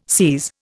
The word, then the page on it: SIS: Indian